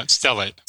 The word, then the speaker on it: stellate: It was a stellate ganglion. Am I pronouncing that right? – Yeah, stellate.